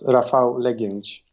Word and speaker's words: Rafał Legiędź: Okay, so it's not an easy name, even for Polish people, but it's pronounced Rafał Legiędź.